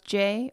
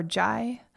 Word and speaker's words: Jai: Today's mantra is Jai Ma, and Jai, it's spelled J-A-I, and I've heard it pronounced Jai or Jai, and then Ma is just M-A, and I'm pronouncing it Jai for this mantra.